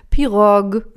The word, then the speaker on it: Pirogue: Ich glaube, es heißt Pirogue. Du kannst es jetzt nochmal schön auf Französisch bitte aussprechen. – Ja, es ist eine Chaise Longue und das heißt Pirogue.